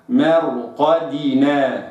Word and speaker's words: مرقدنا: البعض يعني ينطق الدال كأنها مقلقلة، مرقدنا مرقدنا، كأن الدال كده ساكنة، ما يعني قلقلة مقلقلة، قلقلة مائلة إلى الكسر. لا، الدال مكسورة كسرة خالصة، كسرة ظاهرة، فأقرأها ميم مرقدنا وليست ميم مرقدنا هكذا كما يقرأ البعض، وإنما مرقدنا هكذا نطقا صحيحا.